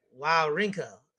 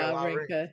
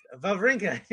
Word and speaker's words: Wawrinka: You know, because it's W-A-W. Yeah, I know. I'm just saying, like, I saw his name. It's like W-A-W, right? R-I-N-K. I'd be like, wow, Rinka. That's a name right there. – Wawrinka. – I was like, that's how you say it, right? And then, you know, not knowing that Swiss people don't say their names like they're spelled, you moron. Yeah. It's Wawrinka.